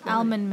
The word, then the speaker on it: almond: Someone said like the L is silent, almond milk. So now I'm very self-conscious when saying the word almond. You know what I mean. Don't judge me. English is hard. – Salmon have the silent L, but not almond.